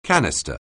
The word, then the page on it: canister: anglais